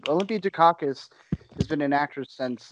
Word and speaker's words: Dukakis: Olympia Dukakis? Is that how you pronounce it, Alex? – What? – Olympia Dukakis? – Dukakis, sorry.